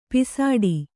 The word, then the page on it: ಪೋಸ್ಟು: pōsṭu; Kannada